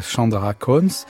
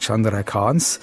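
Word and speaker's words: Chandrakauns: Nous écoutons le raga Chandra Kons. Il faudrait sans doute d'ailleurs prononcer Chak Chandra Kans.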